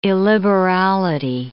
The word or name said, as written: illiberality